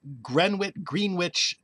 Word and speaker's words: Greenwich: But there are three Greenwich townships in New Jersey, pronounced Greenwich. – Even in New Jersey? – In New Jersey, there are three of them, and I've checked this several times. All three are pronounced Greenwich, which is just incredible.